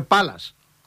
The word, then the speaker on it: Πάλας: Ένας Παγκρατιώτης μου έκανε παρατήρηση και μου λέει, γιατί το λες Παλάς, εμείς όλοι εδώ το λέμε Πάλας.